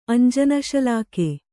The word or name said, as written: ಅಂಜನಶಲಾಕೆ